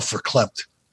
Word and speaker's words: verklempt: But the whole Kevin Sullivan thing maybe had me all verklempt. Another word. That's with a V, not an F. But it's pronounced with a V because it's a Yiddish word.